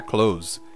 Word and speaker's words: clothes: And now e agora, C-L-O-T-H-E-S, clothes, roupas. E é assim mesmo, parece que eu estou falando o verbo fechar em inglês, mas como o TH seguido de S é muito difícil de pronunciar, ficando clothes, é muito mais comum falar clothes.